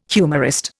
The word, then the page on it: humorist: Indian